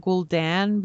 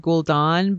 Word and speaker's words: Gul'dan: trying to correct myself on Gul'dan because I've said Gul'dan, but it's Gul'dan.